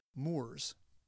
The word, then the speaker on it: Moor: The Spanish eventually referred to the Muslims as Moros, a term derived from the ancient Roman North African province of Mauritania, and which our English forebears pronounced Moors.